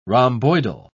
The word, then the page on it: rhomboidal: British English